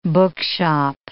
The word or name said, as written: bookshop